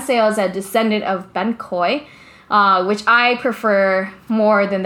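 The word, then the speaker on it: bánh khoái: Let me make sure I'm saying it right. Because that's another dish that I always mispronounce. Because people hear me and they're like, oh, you mean ban khot. And I'm like, no, not ban khot from Vung Tao. I'm talking about ban khoi, which is like a ban sail, but it's thicker and it's crunchier.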